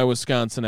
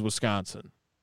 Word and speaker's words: Wisconsin: Well, it is enunciated differently by everyone I know who is not from Wisconsin. The way Wisconsinites say it is the SC is very connected. Wisconsin.